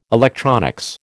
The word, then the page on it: electronics: American English